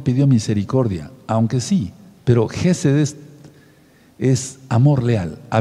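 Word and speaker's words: Gesed (חסד): Pero Geseth se escribe en la fonética hebrea J, la letra E de Ernesto, la letra S, la letra E de y la, de Ernesto y la letra D de dedo, Gesed.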